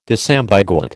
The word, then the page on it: disambiguate: American English